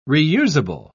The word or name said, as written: reusable